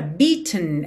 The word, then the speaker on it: beaten: Essa palavra beaten, ela é bem interessante, porque o E não é pronunciado. Então, do T para o N, você faz um tn, tn. É quase como se fosse um som para dentro. Beaten, beaten, ok?